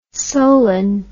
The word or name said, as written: solen